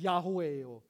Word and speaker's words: Yahweh: And it is pronounced Yahweh or Jehovah.